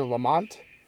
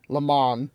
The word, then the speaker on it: Le Mans: Is it Le Mans or Lamont? – I think it's Le Mans. – Le Mans. – But it's spelled Le Mans. – Okay. – Because of French.